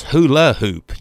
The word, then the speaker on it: hula hoop: It's hula hoop. You said hooly hoop. – Hey, I put the wrong emphasis on the wrong syllable.